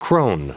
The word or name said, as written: crone